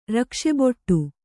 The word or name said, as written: ರಕ್ಷೆಬೊಟ್ಟು